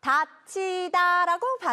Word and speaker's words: 닫히다: 이렇게 평소 우리가 말할 때는 소리 나는 대로 편하게 말하니까 둘 다 다치다라고 발음을 하는 거예요.